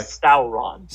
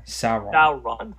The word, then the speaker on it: Sauron: The original King Kong going against Sauron... Am I mispronouncing it? I'm sorry. – Sauron. Sauron from the Lord of the Rings trilogy.